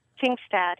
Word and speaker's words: Jingstad: And whoever would come out on top in the primary would face the Republican challenger, which I believe I might pronounce his last name wrong, but it's Matt Jingstad? – Jingstad, yes.